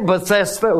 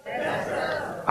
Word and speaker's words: Bethesda: Say Bethesda with me. Bethesda.